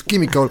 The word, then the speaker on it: Chemical: C'est prononcé Chemical.